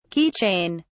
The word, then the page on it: key chain: British English